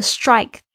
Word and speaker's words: strike: strike 大家特别注意一下这个 strike 的发音，s t r 不是发 strike。而是发 strike，为什么呢？因为在 s 后面的这个清音 tr 要变成 j 这样子的声音哦，这样比较好发。